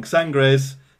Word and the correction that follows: Sagres: Sangres Preta. Sangres Bohemia. – Sagres. – Sagres. Sagres Preta. Sagres Bohemia.